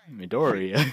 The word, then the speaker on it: Midoriya: So, you've got the main character, Midoriya, or wait, no, how do you say that? I don't know. – Midoriya.